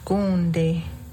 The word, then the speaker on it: gonde: And going back to eel, gonde. – Gonde.